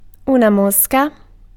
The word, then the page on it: mosca: /ˈmɔs.ka/